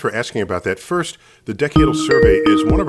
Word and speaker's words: decadal: known as the Decadal, is that how you say it? – Decadal. – Referring to 10 years, decadal. They release one only every 10 years.